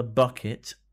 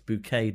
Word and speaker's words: Bucket: The whole running gag is that everybody calls her Bucket and she replies it's pronounced Bouquet, darling, even though it's spelt Bucket, because she's trying to make it look like she's more well-to-do than she actually is.